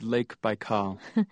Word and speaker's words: Baikal: Am I saying that right? Baikal? – Yeah, it's Lake Baikal.